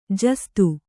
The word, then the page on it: ಜಾಸೂದು: Kannada